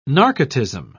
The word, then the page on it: narcotism: British English